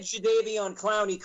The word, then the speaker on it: Jadeveon Clowney: You said Jadeveon Clowney correctly.